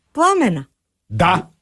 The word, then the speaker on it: Пламена: Разкажи повече за новата песен, защото тя, пламенната жена, всъщност е пламена. – Да, да. – Твоята любима. – Новата песен се казва «Пламенна жена» и е написана за моята съпруга Пламена. – Пламена, да, точно да, така, така ударението.